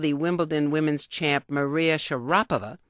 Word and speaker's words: Sharapova: All you letter writers noted that most sportscasters, including those covering Wimbledon, were pronouncing her name Sharapova. Well, actually, the name is correctly pronounced in Russian with the accent on the second syllable.